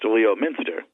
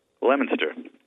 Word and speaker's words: Leominster: By the way, the name of the town is spelled Leominster, but it's not pronounced Leominster. No, it's like the fruit, lemon, Leominster.